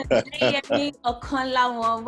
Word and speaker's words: Deyemi Okanlawon: Mr. Deyemi Okanlawon, welcome to the Pilgrimage of Her.